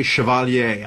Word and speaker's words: Chevalier: And I pronounce it the French way, Chevalier. I don't know if it's Chevalier, like the way, you know, but think about Chevrolet.